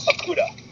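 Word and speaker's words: Okudah: They're going to go with Jeff Okuda, cornerback. – Wow, you actually said his name right for the first time.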